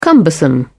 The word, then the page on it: cumbersome: British English